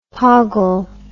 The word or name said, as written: poggle